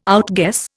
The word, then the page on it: outguess: Indian